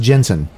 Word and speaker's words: Jentezen: Even cooler is how he spells his first name. – Oh. – It's Jentezen. – Oh, my. – But it's pronounced Jensen.